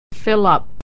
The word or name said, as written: fill up